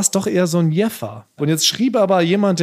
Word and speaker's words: Jever: Also die Stadt, geschrieben J-E-V-E-R, wird tatsächlich gesprochen Jeffa. – Nein. – So wie es ursprünglich mal vor 100 Jahren einer schon gesagt hat. – Jeffa. – Ja, die Stadt selber wird Jeffer ausgesprochen. Das Bier aber tatsächlich Jever mit W, wie Wilhelmine.